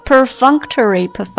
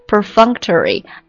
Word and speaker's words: perfunctory: 好，最后一个依然也是高级知识分子啊，读作 perfunctory, 有点像 perfume, 有点像 function, perfunctory, perfunctory, 它是一个形容词啊，表示敷衍的、例行公事的、潦草的等等意思。